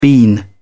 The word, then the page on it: bean: British English